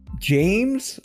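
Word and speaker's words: Jeymes: James, which I think is how you pronounce it. It's J-E-Y-M-E-S.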